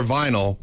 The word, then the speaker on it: vinyl: Remember, for fencing, DLR vinyl rhymes with final.